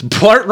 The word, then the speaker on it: Blart: Blart rhymes with fart.